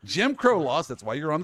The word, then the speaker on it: Jim Crow: Jim John Brown laws. – Jim Did I just Crow. butcher that? – Jim Crow laws.